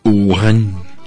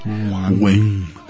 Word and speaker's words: Wuhan: Y fíjate, oh, ironía, eh, estaba leyendo que por las condiciones de vida de la ciudad donde, en China, se pronuncia W está complicado. U H, tiene un H intermedia que hace difícil, pero es Wuhan. Wuhan.